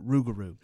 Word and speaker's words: rougarou: In South Louisiana, they often pronounce it rougarou.